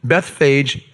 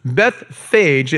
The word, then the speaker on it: Bethphage: Bethphage, if I pronounce it a little bit differently and I turn the soft G into a hard G, you'll get the name of what it is.